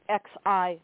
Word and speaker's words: Xi: The X in XI, Xi Jinping, is pronounced Xi.